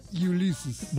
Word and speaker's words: Ulysses: Escuchad mi pronunciación, a ver si me... – A ver. – Ulysses. – Muy bien.